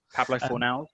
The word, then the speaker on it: Fornals: In recent windows, you have bought the likes of Haller, Anderson, Fornals. Is that how you say his name? Fornals? – Yeah, Pablo Fornals.